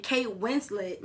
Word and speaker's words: Winslet: And Kate Winslet, that's how you say her name.